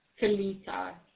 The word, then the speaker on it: Talitha: Felisa.